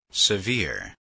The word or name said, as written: severe